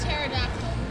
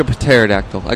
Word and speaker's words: pterodactyl: And the P is silent, but I still say pterodactyls.